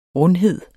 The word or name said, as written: rundhed